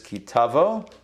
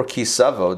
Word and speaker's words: Ki Tavo: Torah portion this week is Ki Tavo or Ki Savo, depending on how you want to pronounce it. I grew up saying Ki Savo, but, you know, the Israeli way and the modern way of saying it is Ki Tavo. So, you know, all us Ashkenazic pronunciations can go out the window.